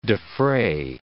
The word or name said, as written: defray